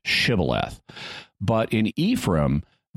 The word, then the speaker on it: Shibboleth: When he said, 'No,' they said to him, 'Then say Shibboleth.' And he said, 'Sibboleth,' for he could not pronounce it right.